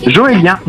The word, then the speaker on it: Joélien: Mais ça se prononce comment ton prénom? – Joélien. J-O-E-L-I-E-N.